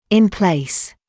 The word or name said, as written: in place